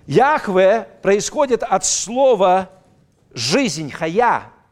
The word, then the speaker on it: Яхве: то большинство сегодня богословов и верующих сходится на том, что, скорее всего, это произносится как Яхва.